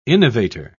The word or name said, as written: innovator